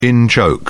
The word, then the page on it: in-joke: British English